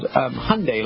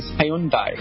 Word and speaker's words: Hyundai: And we were told when the company launched in the United States by the CEO, the American CEO, that we had to be called Hyundai, like Sunday. But actually, the way the Koreans pronounce is Hyundai. And Hyundai. – He got it wrong.